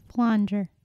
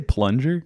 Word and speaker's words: flanger: Oh, did you say plunger? – No, I said flanger. – Flanger. – Is that how you say it? – Flanger. – Oh. – Like flan that flan whatever.